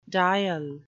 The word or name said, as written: diel